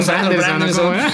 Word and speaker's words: Brandon Sanderson: Brandes. – Pues, digo, Brandon Sanderson. – Nunca lo digo bien.